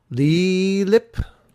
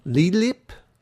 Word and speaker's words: Leixlip: Oh, I can't even pronounce it. L-E-I-X-L-I-P. – Oh. – Leelip. Leelip is the X silent.